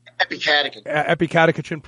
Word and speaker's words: Epicatechin: Catechin? – Epicatechin product and what people can expect.